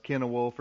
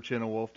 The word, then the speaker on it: Cynewulf: Uh, people have also speculated that it might be a poem of Cynewulf or Chinnewolf, depending on the pronunciation.